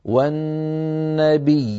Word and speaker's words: والنبيين: والنطق واحد لا يتغير هكذا والنبيين، الياء الأولى مشددة مكسورة والثانية ساكنة مادية،